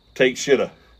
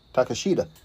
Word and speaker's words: Takeshita: His first name is Konsuke Takeshita. T-A-K-E-S-H-I-T-A. – Takeshita.